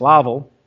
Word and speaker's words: Lowville: I think it's pronounced Lowville. How do you say L-O-W? Yeah, well, they put a ville on it, and it's Lowville.